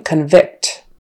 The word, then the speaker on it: convict: The noun often has the stress on the first syllable, as in convict, and the verb often has the stress on the second syllable, as in convict. So convict, onvict, means a person who's in jail. He's been convicted, or she, and they're a criminal. Convict. Convict, since the first syllable is not stressed anymore, that vowel kind of turns into a schwa, convict, convict. The judge convicted the thief.